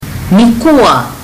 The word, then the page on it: nikua: fiji